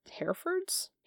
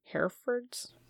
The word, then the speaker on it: Herefords: Finding these young Herefords? Herefords? What's a Hereford? – Like a Hereford with a D? – But it says H-E-R-E-F-O-R-D-S. Like Herefords? – Herefords. – Hereford. Hereford. Is that how you say it?